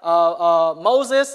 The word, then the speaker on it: Mose: You have Moses, that's how you pronounce it in English. His name is Mose in Khmer.